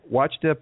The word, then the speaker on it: Ouachita: And uh, Deputy Tramble is, and I am very uh, not very good at pronouncing this, but I'm going to try because it sounds a lot like Watchdogs. But uh, it's uh, Ouachita Parish,